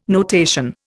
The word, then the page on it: notation: Indian